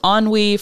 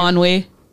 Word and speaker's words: ennui: I always want to pronounce it NUI, and yeah. I'm like, that's not what that is.